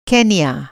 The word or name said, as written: केन्या